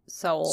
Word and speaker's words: Seoul: Chorwon, a city about 60 miles north of Seoul. Seoul? Is that how you say it? I think that's how you say it. Anyway. – S-O-E-L? – S-E-O-U-L, actually. – S-E-O. Soul. Soul.